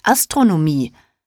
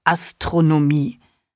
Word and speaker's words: Astronomie: Quatsch, das heißt Astronomie. Oder war es Astrologie? Antwortete Jenny. Benny war es peinlich, dass er das Wort falsch ausgesprochen hatte. Nein, du hast recht, das heißt Astronomie.